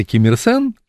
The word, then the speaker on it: Ким Ир Сен: Это китайская транскрипция китайских иероглифов, которая по-корейски читается Ким Ир Сен, по-китайски они читаются Динь Жи Чен.